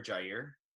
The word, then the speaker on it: Jair: So I prefer Jair. It sounds cooler.